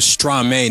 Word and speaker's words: Stromae: We played an artist by the name of Stromae now, and that was Papa Ute.